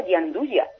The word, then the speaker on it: gianduja: Es que esa palabra es muy complicada para mí porque se escribe gianduja, pero luego se pronuncia yanduya.